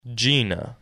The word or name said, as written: Gienah